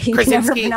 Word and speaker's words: Krasinski: Krasinski.